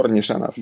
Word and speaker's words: Nishanov: Yes, Gore Nishanov.